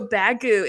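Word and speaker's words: Baggu: So Bagu, it's spelled B-A-G.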